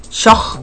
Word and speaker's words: choc: El azul era un color sagrado para la cultura maya y antiguamente se le hacía referencia como choc, pronunciado como shoj, y se produjo mayoritariamente durante el periodo que se extiende del siglo 8 hasta el 1860 de nuestra era.